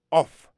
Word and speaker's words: cough: Se C-O-U-G-H, cough. Alors, puis fort mot, majorité mot qui finit en anglais par O-U-G-H, yo prononce off, nan san off la. Ou man, quand on aime de akay, ou kon dit kon sa, yon krapo kap di if, f, off. Bon, c'est off ça.